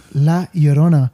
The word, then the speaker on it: La Llorona: Llorona. – Oh, La Llorona. – La Llorona.